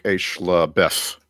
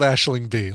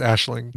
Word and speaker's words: Aisling Bea: And she's Irish, so her name is probably pronounced like Aishla Beth. – Yeah. – I think it is called Aisling Bea. Like, I think from that Taskmaster season, I think we finally learned how to pronounce it.